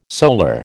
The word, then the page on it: solar: American English